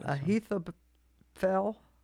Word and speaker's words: Ahithophel: A-H-I-T-H-O-P-H-E-L. – Where is this? – Ahithophel.